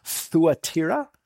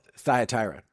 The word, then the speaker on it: Thyatira: Technically in Greek, it's pronounced Thuatira, but we'll call it Thyatira in honor of my southern grandparents.